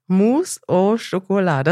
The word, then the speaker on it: Mousse au Chocolat: Mir fällt das auf, weil ganz viele Produkte, die ich jahrelang nicht gegessen habe, zum Beispiel Mousse, Mou, ist, heißt das Mousse au Chocolat oder habe ich das jetzt falsch gesagt? – Deine französische Aussprache ist excellent. – Mousse au Chocolat.